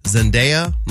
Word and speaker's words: Zendaya: So her full name is actually Zendaya Marie Stormer Coleman. – How are we pronouncing her name? – I think it's Zendaya.